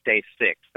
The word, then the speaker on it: STACYC: And it's it's spelled kind of weird: STA for stability, and then CYC for cycle, stability cycle, and they pronounce it stay sick.